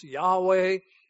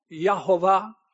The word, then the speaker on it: Yahweh: Only the one and only true God whom the Bible calls the YHWH, the Tetragrammaton, sometimes pronounced Yahweh, but maybe more properly pronounced Yehovah, the great God, and then the anglicized version is Jehovah.